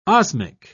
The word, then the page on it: osmic: British English